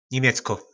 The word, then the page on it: nimecko: po nasimu